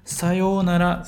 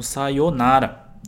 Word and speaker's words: sayonara: Que no Brasil a gente costuma pronunciar como sayonara, tá? Mas a gente sempre prolonga esse yo.